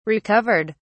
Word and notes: recovered: British English

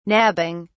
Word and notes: nabbing: British English

